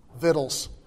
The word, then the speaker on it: victuals: Oh, and by the way, if you look up proper pronunciation on victuals, guess what you're going to find? Vittles.